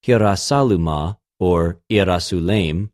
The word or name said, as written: Ἱεροσολυμα